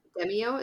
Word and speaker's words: Demeo: De- Demeo? Is that how you pronounce that? – Yeah.